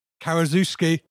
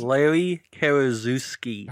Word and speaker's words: Karaszewski: The film is written by Scott Alexander and Larry, I always get this name wrong, Kowalski, and stars Karaszewski.